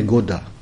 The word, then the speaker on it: Goda: Mon nom est Goda, G-O-D-A. Peut-être ce n'est pas difficile pour vous de le prononcer comme God, comme Godard, etc.